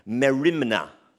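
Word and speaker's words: merimna (μέριμνα): If you're taking notes, it's in the English language, it's spelled R-M-E-R-I-M-N-A, merimna. Say it out loud, merimna.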